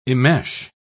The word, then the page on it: immesh: British English